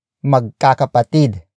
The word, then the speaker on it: magkakapatid: Word for word, it's pang-ilan ka sa magkakapatid.